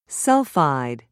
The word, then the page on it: sulfide: British English